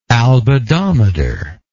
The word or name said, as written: albedometer